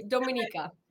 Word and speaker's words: Dominika: Dominika.